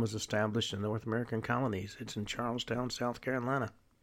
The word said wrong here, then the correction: Charleston: Or Charleston, as it's pronounced usually.